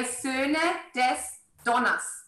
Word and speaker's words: Söhne des Donners: Söhne, because it's an umlaut au and you pronounce it like an ö. So you would say Söhne des Donners.